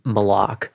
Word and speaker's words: malak: And so in the Old Testament, the key term is malak, is how you pronounce it. M-A-L-A-K, if you're going to transliterate.